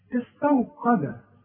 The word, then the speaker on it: استوقد: استوقد استو شوف استو غلط عارف ليه تنطقها زي ايه زي استوى شوف استوى استو قا ما انا بقول لك ادي كل حرف حقه في القران القاف